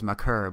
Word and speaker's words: Maker: You know, Thon Maker is really, it's Maker, but everyone, you know, the correct pronunciation apparently is Maker, but that's not going to stick on, so I'm going to say Maker.